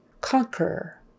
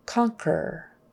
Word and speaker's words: conquer: But then we have words like conquer, conquer, U is silent, turquoise, turquoise, what a pretty turquoise ring you have.